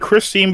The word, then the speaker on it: Khrystyne: No, it's like Christine, but it's spelled really weird.